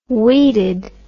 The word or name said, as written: weeded